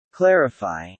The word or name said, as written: clarify